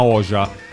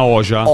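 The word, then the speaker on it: Hoxha: Il suo cognome vero si scrive H-O-X-H-A, e in albanese XH si legge J. E quindi Anna Oja.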